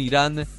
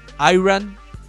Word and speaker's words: Irán: Incluso hoy en la rueda de prensa, Iran eh, fue la, pronunciación de, mm, del capitán de los Estados Unidos. Eh, un periodista le dijo que no, que no se pronunciaba así, que era Irán, no Iran.